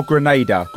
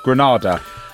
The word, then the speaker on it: Granada: correct me if I've got the pronunciation wrong, Granada or Grenada? Granada. – Grenada is a Caribbean island. – Island, yes. – Granada is España.